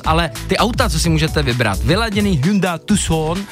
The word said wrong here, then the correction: Hyundai: To se vyslovuje jinak. – Je to tak. – Hyundai, jak v té reklamě, Hyundai.